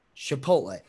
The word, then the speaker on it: Chipotle: That's how you pronounce it. Chipotle.